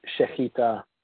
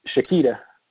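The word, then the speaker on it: shechita (שחיטה): Hebrew, that's called shechita. – Okay. – Or shechita, if you want to pronounce it like a gringo.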